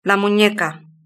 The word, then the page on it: la muneca: Spain Spanish